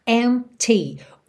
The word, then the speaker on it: empty: Empty. O P é mudo, tá? E o T é marcadinho. Empty. Empty space.